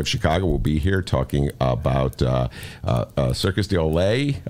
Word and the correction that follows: Cirque du Soleil: I may have butchered, butchered that. Cirque du Soleil.